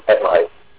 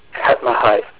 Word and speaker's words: Hetmehyt: Again, that god is Hetmeyet. I'm probably mispronouncing it because I do not speak Egyptian. – Hetmeyet, it sounds right. – H-E-T-M-E-H-Y-T. – Yep. Hetmeyet, and he's literally a god of sustenance for them.